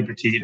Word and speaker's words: Petit: I mean, it's Anthony Petit. It's like, think about small, cool.